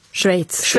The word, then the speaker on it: Schweiz: Och du säger det helt perfekt. Schweiz, det är inte helt lätt. – Ja. Ja. – Ett ord med många uttal.